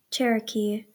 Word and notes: Cherokee: / ˈ tʃ ɛr ə k iː , ˌ tʃ ɛr ə ˈ k iː /

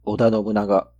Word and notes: 織田 信長: o.da no.bɯ(ꜜ).na.ɡa, -na.ŋa

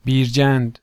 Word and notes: بیرجند: biːɾˈdʒæːnd; Persian

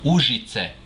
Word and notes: Ужице: ûʒit͡se

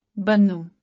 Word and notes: بنوں: bannū̃; [bənːũː]; Urdu

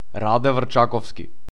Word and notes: Раде Врчаковски: ˈraːdɛ vr̩ˈt͡ʃakɔfski

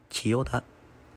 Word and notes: 千代田区: Chiyoda; [tɕijoda]; Japanese

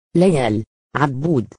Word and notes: ليال عبود: Layal Abboud; [laˈjaːl ʕabˈbuːd]; Arabic; female

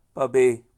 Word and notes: پبى: Pashto

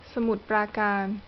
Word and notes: จังหวัดสมุทรปราการ: t͡ɕāŋ.wàt̚ sàmùt prāːkāːn; Thai

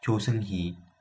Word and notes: 조승희: [tɕo sɯŋhi]; Korean

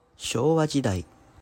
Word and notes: 昭和: Shōwa; [ɕoːwa]

